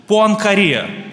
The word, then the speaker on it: Пуанкаре: Он доказал гипотезу Пуанкаре, называется она так, ударение именно на последнюю букву.